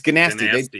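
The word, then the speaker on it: Gnasty Gnork: It's Ganasty. They do. – Yeah, they make a point of pronouncing that G, man.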